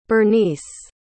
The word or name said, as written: bernice